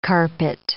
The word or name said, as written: carpet